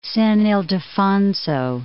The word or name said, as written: San Ildefonso